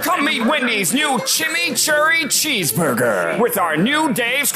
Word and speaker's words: chimichurri: Introducing Wendy's new chimichurri cheeseburger.